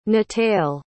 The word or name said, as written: nataile